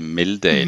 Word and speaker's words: Meldahl: Man skal udtale det Meldahl. – Meldahl. – Ja, jeg har hørt. – Ja, det er jo til trods for, at det staves M, altså hvis det var M-E-H-L, så kunne man måske bedre forstå det.